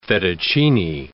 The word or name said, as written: fettuccine